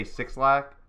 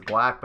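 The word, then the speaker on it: 6lack: Well, because like how you pronounce it is Black, but like everyone just read it and said Six Lack.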